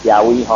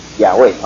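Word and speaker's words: 雅威: 我来讲义啊，第四章第六节，耶和就是耶和华哈，亚威哈，因为伊对来文的发音就是耶和亚威哈。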